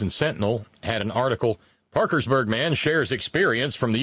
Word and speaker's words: Parkersburg: Eric Eugene Barber is, of course, a former city councilman, want to guess his politics, from Parkersburg, That's how we pronounce it here. It's Parkersburg, but it's Parkiesburg, West by God, Virginia.